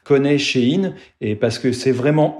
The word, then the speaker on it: Shein: C'est à cette époque que le nom a été raccourci en Shein, alors qu'il faut prononcer du coup à l'anglaise Shein.